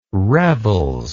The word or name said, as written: revels